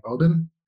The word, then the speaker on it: Oban: I do pronounce it that way, although I've learned somewhat recently in a funny kind of way that that's not how I'm supposed to pronounce it. It's actually more like Oban.